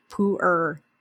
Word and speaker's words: pu'er: So sometimes people say pu'er. Sometimes people say pu'er. So my father-in-law is Chinese, and I asked him at the time, he's passed away since, but I asked him, like, can you please pronounce this so I don't screw it up? But the way he pronounces it is pu'er. So that really hard R sound.